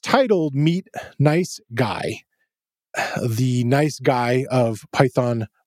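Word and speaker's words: NiceGUI: I featured it as a library during my project section there, and I pronounced it nice GUI, kind of emphasizing the GUI of the graphical user interface of it. But supposedly, they would like it pronounced as guy.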